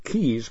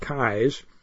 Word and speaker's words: Keyes: It's spelled K-E-Y-E-S. You'd think it would be Keys, but they pronounce it Kai's.